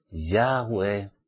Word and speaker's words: Yahweh: Y siempre que vayáis al Padre, pronunciad su nombre, Yahweh. Yahweh.